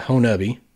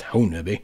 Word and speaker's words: Honobia: I remembered many years ago, like in 2008 and 9, on a TV show I worked on, we covered the Honubi, spelled Honobia, but I promise you, I'm from Southeast Oklahoma. It's pronounced Honubi.